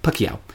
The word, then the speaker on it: Pacquiao: So that was particularly unhelpful, but it is Manny Pacquiao. I've been pronouncing it wrong, and I think a lot of other people have, but Pacquiao. Manny Pacquiao.